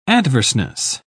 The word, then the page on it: adverseness: British English